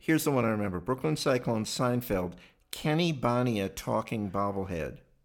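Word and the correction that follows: Bania: Banya. It's pronounced Banya.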